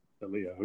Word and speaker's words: Shalia: Yes, I mean, you put an extra emphasis on that Sha, but it's Shalia. Shalia. And what I like to tell people is that it rhymes with Aaliyah, and that kind of helps to cement with the word association.